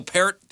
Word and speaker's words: Peart: Neil Peart, the amazing drummer and lyricist for Rush, is 66.